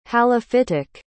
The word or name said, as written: halophytic